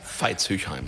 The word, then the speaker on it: Veitshöchheim: Und Veitshöchheim, wenn ich es jetzt richtig ausgesprochen habe? – Veitshöchheim.